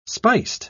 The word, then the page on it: spiced: British English